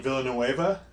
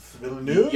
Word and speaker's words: Villeneuve: Villanueva. V- Villeneuve. – Villeneuve. Yeah, it's you, not Villanueva. Yeah, you dropped the Nueva.